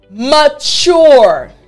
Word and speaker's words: mature: Now, you notice the word has a T in the middle. However, I want to break down the pronunciation for you, all right? So, the word is mature. Again, that's M A-T-U-R-E. But the last part of the word has a very unique sound, the middle and the last portions of this word. So, T-U-R-E. Mature. Mature. So, even though it's spelled T-U-R-E, It sounds like C-H-U-R-E. The ch, ch sound.